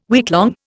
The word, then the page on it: weeklong: Indian